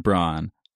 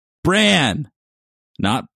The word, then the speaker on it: Bran: Number two, one of the people in this podcast has a tendency to pronounce Bran's name Bron. Stop it. Bran, not Bron.